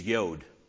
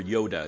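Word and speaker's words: yodh: And phonetically, iota sounds like another very small Hebrew letter, the smallest letter in the Hebrew alphabet, which is yod or yoda. It's Y-O-D-H. He's not referring to Yoda, a very small green Jedi master, but he is referring to yod.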